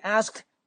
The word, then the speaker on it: asked: 然后呢，he asked me，他问我这个 asked 啊，正常来讲，这个 ed 呢应该发成 t 的发音。 he asked me，但是一个 t 你听到发音吗？其实我没有听到发音，为什么？因为我们之前跟他讲过，当 t 这个字母，当 t 这个音标在结尾的时候，尤其他后面接一个辅音，这个 t 就不发音了，所以你听到的直接就是 and he asked me。